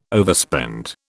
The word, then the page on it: overspend: British English